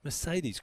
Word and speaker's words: Mercedes: But Mercedes, is it Mercedes? It's Mercedes College. – Yep. – That's how they pronounce it.